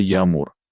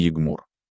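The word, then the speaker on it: yağmur: Джи не читается как Г. Екмёр, дождь, это Ямур, а не Егмур.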